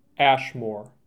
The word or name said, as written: Ashmore